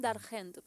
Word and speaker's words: La Femme d'Argent: Y menos esto que parece estar en francés, pero es La Femme d'Argent. – Sí. – Así, d'Argent. – La Femme d'Argent, porque es d'Argent.